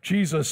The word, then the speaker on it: Jesus: He goes by Jesse, but his legal name is spelled J E S U S, pronounced Jesus.